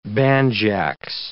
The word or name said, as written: banjax